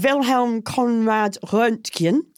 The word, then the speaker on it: Wilhelm Conrad Röntgen: And a German scientist who was called Wilhelm Conrad Röntgen.